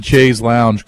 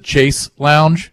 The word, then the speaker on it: chaise lounge: By the way, I'm glad that you pronounced the chaise lounge correctly. Eric always pronounces it chase lounge, and it just tilts me.